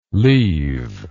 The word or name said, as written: lieve